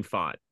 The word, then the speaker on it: Pfaadt: I don't know if it'll happen, but what do we think about going into there against, is it Faft? I don't know how you say his last name. – Fod? I don't know. – How do you say that? – Fod. Wait, can you hear me? – Yep. – Yeah. – Can you hear me? – Yeah. – Okay, so yeah, it's Brandon Fod. – Fod, okay. – That's right, yeah. – Yeah, P-F-A-A-D-T.